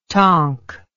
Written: tonk

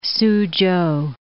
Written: Soochow

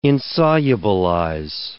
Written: insolubilize

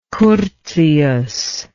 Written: curtius

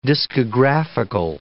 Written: discographical

